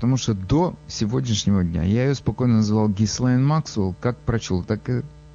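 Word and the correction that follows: Ghislaine: Она, значит, родилась во Франции. И имя у нее французское. И по-французски ее надо произносить Жислен. Жислен Максвелл.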